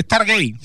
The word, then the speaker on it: Stargate: Se escribe Stargate. Stargate, sí. – Pero en inglés se dice Stargate.